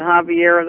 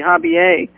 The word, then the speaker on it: Javier: Well, Javier, Stan, I guess I have Javier on the brain, he generally pronounces it, the most various commentators have pronounced it Javier, the correct pure Spanish pronunciation would probably be Javier. Solana, but he pronounces, I've heard him himself pronounce it Javier, so he's probably kind of Americanized it.